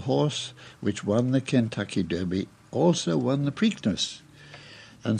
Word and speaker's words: Derby: The great American horse race is, you must know, the Kentucky Derby, pronounced Derby for interesting reasons we'll go into some other time.